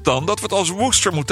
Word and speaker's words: Worcestershire: Wat veel mensen uitspreken als Worcestershire sauce. De saus is ooit uitgevonden in Worcester, in het graafschap Worcestershire. Maar het is toch echt de bedoeling deze tongenbreker uit te spreken als Worcester.